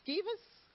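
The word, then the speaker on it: Sceva: And we see it in Bible with Skevas, Skevas, that the way his name is pronounced.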